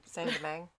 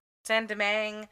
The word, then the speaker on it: Saint-Domingue: Saint-Domingue. – Saint-Domingue. Yeah, because I was like, is it same as Saint-Dominique? But it's not. So Saint-Domingue, apparently sometimes it can rhyme with meringue.